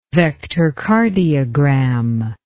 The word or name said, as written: vectorcardiogram